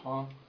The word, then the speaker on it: Huang: My name is James Huang. First name is spelled J-A-M-E-S. Last name is H-U-A-N-G.